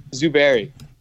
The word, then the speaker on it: Zuberi: Zuberi.